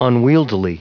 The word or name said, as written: unwieldily